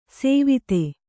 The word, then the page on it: ಸೇವಿತೆ: sēvite